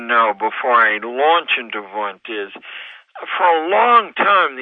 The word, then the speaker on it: Wundt: Uh, well, Wundt is uh, how it's pronounced, although it's spelled Wundt.